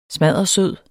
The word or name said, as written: smaddersød